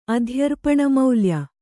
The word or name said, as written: ಅಧ್ಯರ್ಪಣಮೌಲ್ಯ